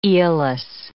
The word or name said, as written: Aeolus